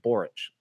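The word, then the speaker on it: Borics: Everyone speaks about Pico's derailed hype train, but nobody seems to notice the breakout talent that is Adam Boryx. Pronounced Boric.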